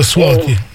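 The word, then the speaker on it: złoty: Sì, la moneta si chiama, sta scritto sloti, ma si pronuncia swoti.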